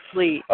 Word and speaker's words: Leigh: It's pronounced Lee, but you got it right. So that's it. – Lee.